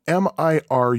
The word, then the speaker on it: Miru: It is spelled M I R U, but I'm pretty sure that it's pronounced Mir because Japanese words tend to have a silent U at the end.